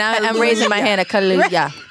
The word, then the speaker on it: Kaluuya: Kaluuya. – Like Hallelujah. Kaluuya.